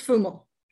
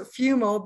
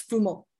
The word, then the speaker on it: Fumo: It's, it's Fumo. Okay, I didn't want to butcher it. I'm the queen of butchering names. – No, that's okay. – Most so, uh, most English people pronounce it Fumo, but in Italian it's Fumo.